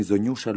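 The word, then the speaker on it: zonjushë: Ju jeni Zonjusha Luarasi.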